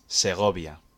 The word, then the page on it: Segovia: Spanish